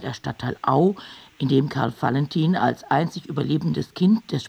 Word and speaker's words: Valentin: Auer waren, so hieß es, brutal und das Kind Valentin, der sich später Karl Valentin nannte, nicht wie Wall, sondern wie Fall, entwickelte früh ausgesprochen sadistische Züge im Umgang mit Spielkameraden.